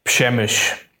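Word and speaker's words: Przemyśl: The secret tip I got from some of our Polish fans was that the first mistake that we Westerners always make is to assume that it's a word with three or four syllables, even though it only has two. So, Przemysl. It's the place,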